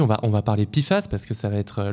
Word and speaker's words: PFAS: Et donc, pour commencer, de quoi parle-t-on Qu'est-ce que c'est que les PIFAS, Pierre alors, les PFAS, les PFAS, donc à prononcer PFAS, c'est déjà quatre lettres pour une famille de produits chimiques franchement pas facile à prononcer.